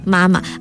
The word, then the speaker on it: 妈妈: Mama. Properly in Chinese, the pronunciation is supposed to be mama.、 Hmm. – So the second tone is actually a light tone.